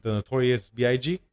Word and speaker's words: The Notorious B.I.G.: Me parece que pronuncias todos mal. El Notorious BG. ¿O cómo lo pronuncias ese güey? – ¿Los BGs? – The Notorious B.I.G.